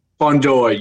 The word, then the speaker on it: Fundora: Fundora. Yes, Fundora.